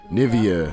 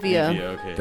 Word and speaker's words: Nivea: Nivea. – Nivea. – Nivea, okay.